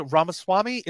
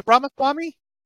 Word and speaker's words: Ramaswamy: How is how do you pronounce it? Ramaswamy? Is it Ramaswamy? Um, yes, I think so.